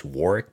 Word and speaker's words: Warwick: It's actually pronounced Warwick, but it's still spelled the same way, W-A-R-W-I-C-K.